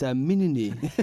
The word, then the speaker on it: Menini: Is that how you would pronounce that? I know you're very good with French. – Yeah, you've heard me on the other podcast. Yeah, that's Menini. That's pronounced Michelle Menini.